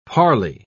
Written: parley

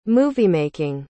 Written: moviemaking

